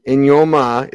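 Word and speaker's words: Eyioma: Even though Ioma and Inyoma is the way you pronounce it correctly, there's no N in it, but there's pronounced with an N.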